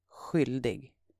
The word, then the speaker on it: skyldig: It's spelled S-K-Y-L-D-I-G. Write that down and try to pronounce it. It's pronounced skyldig, skyldig. And the Swedish word for charming is charmig. It's spelled C-H-A-R-M-I-G.